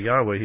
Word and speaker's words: Yahweh: It's YHVH, but we pronounce it with the vowels and make the V a W because a V says woo, so it's Yahweh.